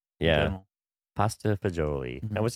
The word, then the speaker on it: fagioli: So it might be fagioli, which is pasta with beans. – Is that how you say it? – Yeah, so fagioli is.